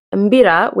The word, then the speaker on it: Mbira: So like traditional dancing and like Mbira players. So Mbira, which is spelled M-B-I-R-A, some people would think is called the Kalimba. It is the thumb piano.